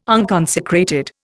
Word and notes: unconsecrated: Indian

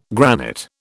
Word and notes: granite: British English

